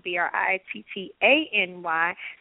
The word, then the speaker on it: Brittany: My personal social media pages are at Miss, and that's M I S S Brittany, B R I T T A N Y. So it's Brittany, really. And that's how you really should say my name, but I don't say it that way.